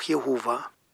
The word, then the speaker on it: Jehova: Diejenigen, die wie im hebräischen Text den Eigennamen Gottes von den Titeln Gott und Herr unterscheiden, fügen traditionell die Vokale A und E oder auch E, O und A hinzu. Und so kommt man dann zu der Aussprache Jahwe oder Jahwe oder auch Jehova.